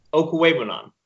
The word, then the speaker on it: Okwuegbunam: Albert Okwuegbunam, right? – No, Okwuegbunam. – Okay, what? – So that K-W-U-E-G, one yes. syllable, and it's like Quay. So Okwuegbunam.